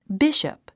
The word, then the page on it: bishop: bíʃəp; ビシャップ